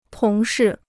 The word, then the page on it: 同事: tóng shì